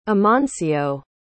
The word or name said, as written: amancio